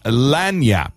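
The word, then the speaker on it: lagniappe: But today's word, I'd love for you to try and spell for us, is lanyap. Lanyap. – Lanyap. – Lanyap. – That's what I said, although a little emphasis on the second syllable.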